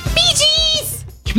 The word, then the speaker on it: Bee Gees: Das ist ganz falsch gesagt. Das heißt doch anders. – Bee Gees! – Bee Gees.